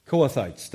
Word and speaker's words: Kohathites: Remember the Kohathites? I'm saying it wrong. We read about them, the tribe of Israel. Kohathites, thank you.